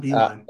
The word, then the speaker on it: Beilein: B, Beilein. – yeah, John uh, Beilein.